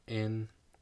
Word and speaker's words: INVU: So we're gonna start with Taeyeon, who came out with INVU, which is INVU, like the letters, is how it's pronounced, you know.